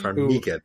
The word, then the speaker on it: Farmiga: Farmiga, that's how you pronounce it.